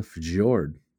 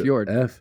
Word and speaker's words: fjord: But then it says it's deeper than a bite and wider than a fjord. It's literally fjord. F-J-O-R-D. It's a fjord. – Fjord. – Is that how you say that?